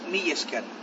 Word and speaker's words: Mila esker: Decimos o muchas gracias o mil gracias, que es mil esker. ¿Vale? Mila esker se escribe.